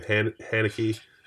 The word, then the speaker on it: Haneke: Likewise, and I just want to apologize to everyone that we went back and forth between pronouncing his name Haneke and Haneke. For years, we've been calling him Haneke. And just recently, we discovered that Haneke might be the correct pronunciation.